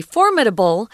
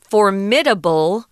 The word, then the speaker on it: formidable: There are two pronunciations for this word formidable or formidable. They're both acceptable. Um, you can pick the one you want. I say formidable.